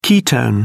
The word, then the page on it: ketone: British English